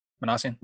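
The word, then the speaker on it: Minasian: I kind of get an idea where Parmenasian's been. Did I say that right? – Manassian. Yeah. Manassian.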